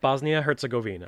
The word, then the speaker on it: Bosnia-Herzegovina: Bosnia-Herzegovina.